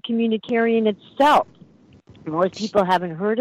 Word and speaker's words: communitarian: I couldn't pronounce it properly for the first 6 months. I called it command humanitarian.